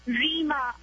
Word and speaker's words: Reema: Rima. R-E-E-M-A.